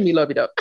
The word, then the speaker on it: Milovidov: Did I say it right? – You know you did. – I did. Okay. – Yeah. – Not phonetically. Milovidov. Okay.